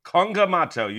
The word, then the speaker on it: Kongamato: Am I saying that right? Kongamato? – Kongamato. You got it.